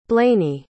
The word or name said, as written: blayney